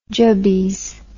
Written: jubbies

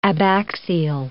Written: abaxial

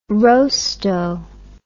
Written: rostow

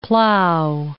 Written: plow

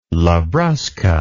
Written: labrusca

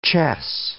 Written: chess

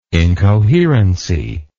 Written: incoherency

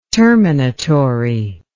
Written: terminatory

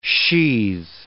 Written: sheathe

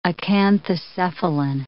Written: acanthocephalan

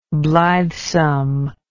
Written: blithesomeness

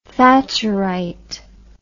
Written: thatcherite

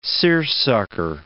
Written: seersucker

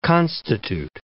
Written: constitute

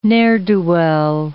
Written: ne'er-do-well

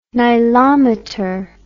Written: nilometer

